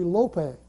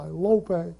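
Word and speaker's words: lope (λύπη): If you were to pronounce it correctly, it would be lope. L-O-W-P-A-Y, lope.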